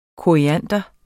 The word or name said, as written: koriander